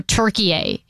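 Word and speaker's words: Türkiye: But it didn't that push for Turkey A instead of Turkey didn't begin until 2021.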